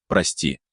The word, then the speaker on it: Прости: но процветающей деревне под интересным названием «Прости», ударение на букву «О».